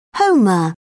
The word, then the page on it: homer: British English